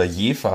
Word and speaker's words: Jever: Ich trinke heute ein Jever Pilsner, ein sehr schönes norddeutsches Bier oder Jefer habe ich mir jetzt sagen lassen, spricht man es eigentlich aus.